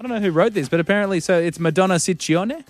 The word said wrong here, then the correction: Ciccone: Ciccone. – Ciccione. What is it, sir? – Well, in Italian, it works the other way. So the double C's are hard C's and the singular C's are soft C's.